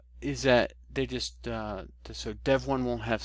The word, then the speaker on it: Devuan: Yeah, so the folks that forked it are calling it Devuan, Devuan, which according to the information they publicized on it, it's not Devuan, as you might think. It's actually supposed to be pronounced Devuan.